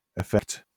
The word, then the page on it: effect: British English